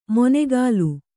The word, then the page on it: ಮೊನೆಗಾಲು: monegālu